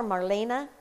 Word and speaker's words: Marlenia: But Skirkas, it's S-K-R-E-K-A-S. Skrekas, maybe it's Skrekas. Is Marlenia. I call her Marlena, but it's spelled Marlenia, but nobody calls her that. Everybody calls her Marlena.